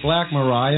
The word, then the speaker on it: Black Mariah: Well, this song is called Black Mariah, and if you'll notice, it has four syllables in it, which means it's a four-syllable titled song.